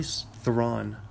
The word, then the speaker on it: Theron: Actually, Theron. Yeah, that's right. Charlize Theron is how it's supposed to be pronounced.